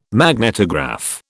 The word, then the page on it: magnetograph: British English